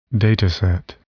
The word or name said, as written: dataset